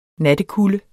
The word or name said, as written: nattekulde